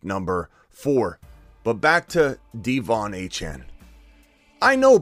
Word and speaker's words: De'Von Achane: No one's saying De'Von Achane's going to go out, and that's how you say his name. He corrected the record, and I went and made sure I watched the footage or watched the release of it. It's not even De'Von. It's De'Von Achane. He wanted everybody to correct both ways to pronounce both his first and last name.